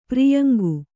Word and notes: ಪೊರಯಿಂಕೆ: porayiŋke

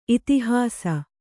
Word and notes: ಇತಿಹಾಸ: itihāsa

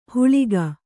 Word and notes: ಹುಳಿಗ: huḷiga

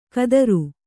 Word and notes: ಖಾತ್ರಿ: Kannada

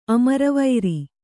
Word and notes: ಅಮರವೈರಿ: Kannada

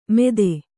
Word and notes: ಮೆದೆ: kannada

